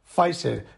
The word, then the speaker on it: Pfizer: Bueno, me han hecho pausar el podcast porque me han dicho que lo he pronunciado mal, que no es la Pfeiffer, es Pfizer.